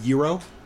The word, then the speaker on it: gyros: I'm pretty sure it's Greek and it's pronounced Euro.